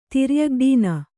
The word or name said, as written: ತಿರ್ಯಗ್ಡೀನ